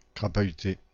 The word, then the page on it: crapahuter: [kʁa.pa.y.te]